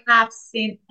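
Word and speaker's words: Haft-Seen: I think I'm pronouncing that right. – Yeah, perfect. – Okay, can you describe it? And I expect with your interior design background, yours is amazing. – Thank you. Yeah, I want to talk about the half-seen, as you pronounce it in a perfect way.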